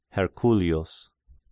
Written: Herculius

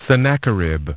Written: Sennacherib

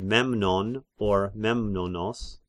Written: Memnon

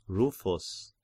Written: Rufus

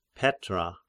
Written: Petra

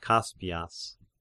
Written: Kaspias

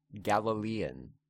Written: Galilean

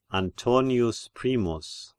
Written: Antonius Primus

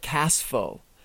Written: Kaspho

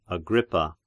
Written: Agrippa

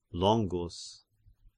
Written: Longus

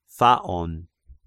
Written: Phaon

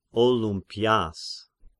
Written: Olympias

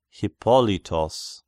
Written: Hippolytos

